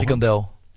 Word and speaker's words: Frikandel: Frikandel, habe ich das richtig ausgesprochen? – Frikandel. – Frikandel? – Ja.